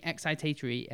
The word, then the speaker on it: excitatory: Excitatory, yeah.